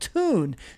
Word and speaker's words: Thun: Well, I have one, and it's a cool company over in Italy called Tune. It's spelled T-H-U-N, but it's titled How Tune Created a Best-in-Class Loyalty Program for Its Customers.